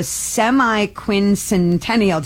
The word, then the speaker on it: semi-quincentennial: It's called the semi-quincentennial.